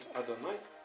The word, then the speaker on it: Адонай: А дальше с именем моим Господь, мы знаем, что имя Господь, оно в иврите звучит Адонай.